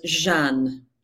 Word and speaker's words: Jeanne: Almost Jeanne. – Jeanne, the French version.